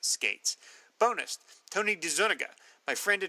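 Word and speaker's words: Skeates: I read an interview Steve Skates gave in either the Charlton Spotlight, Back Issue, or Alter Ego, I think, where he set the record straight on how his name is said. My memory's a bit fuzzy, but I recall him saying that it's pronounced Skates.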